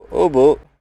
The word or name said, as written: Hobo